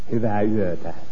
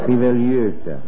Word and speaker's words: Hyvää yötä: How do you say good night? – Hyvää yötä. Well, that's really hard. – Hyvää yötä.